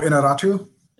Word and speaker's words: Iñárritu: Oh, Iñárritu.